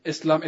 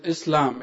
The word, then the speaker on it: Islam: Islam, it is Islam. It is not Islam, or it's not with a Z, it's with an S.